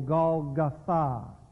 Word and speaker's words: Golgotha: And notice from verse 22 that it says that his execution was a place outside the city called Golgotha. Or, with the emphasis on the last syllable, Golgotha, the place of the skull.